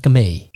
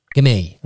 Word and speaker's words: Gamay: Of het Gamay? – Gamay. – Dat hadden we namelijk ook. Hoe spreek je het uit? Ja, Gamay. Tijdens mijn lessen heb ik niet de uitspraak geoefend.